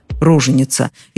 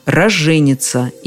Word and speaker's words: роженица: Знаю, что даже в родильных домах это слово частенько произносится иначе – «роженица». Используют даже и такой экзотический вариант, как «роженица». Но правило сурово и однозначно – «роженица», и никуда от него не деться – роженица и новорожденный.